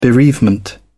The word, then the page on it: bereavement: British English